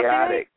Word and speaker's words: Kayotik: So DJ Kato. Kito. Did I pronounce chaotic. – It right? – Chaotic. – Chaotic. I'm sorry.